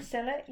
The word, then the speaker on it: Scilla: Scilla, am I pronouncing that right? – Scilla, yeah.